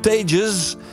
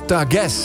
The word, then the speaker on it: Tages: Naar de band, die zouden wij uitspreken als de Tages, maar je schijnt het uit te moeten spreken als Tages.